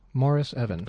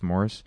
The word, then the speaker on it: Maurice Evans: I always thought his name was Maurice Evans, but his name's Morris. Maurice Evans. – Pronounced Morris? – It's pronounced Morris.